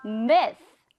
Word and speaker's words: myth: 영어로는 myth. 그렇죠. 끝에 발음이 th, th, th, myth, myth, myth.